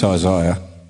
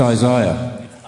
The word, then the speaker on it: Isaiah: Phil, it's Isaiah. – I didn't hear that. – It's Isaiah. – Oh, he's trying to get you to Isaiah. – Pronounce it right. – The I is more in the middle.